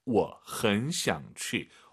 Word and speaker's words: 我很想去: 주어부터 삼성이 연달아 세 개면은 맨 앞에 주어는 보통 반삼성이 맞습니다. 我很想去 이렇게 여러분 발음하시기 바랍니다. 我 올리지 마시고 我很想去 엄청 가고 싶은데 오늘 시간이 없네.